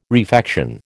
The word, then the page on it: refection: American English